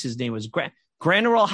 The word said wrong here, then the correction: Graterol: How do I pronounce his name? – Graterol.